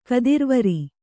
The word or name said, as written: ಗೋತ್ರವೈರಿ